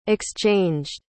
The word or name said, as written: exchanged